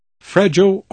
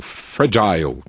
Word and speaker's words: fragile: Aliás, fragile ou fragile, tanto faz, no caso da palavra frágil.